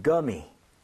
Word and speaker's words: gummy: 그런 것을 이렇게 표기하는데 대신에 우리는 구미라고 하는데 영어에서는 gummy라고 gummy. – 하게 됩니다. Gummy, right? So 구가 아니라 g에 가깝게 발음을 하셔야 됩니다.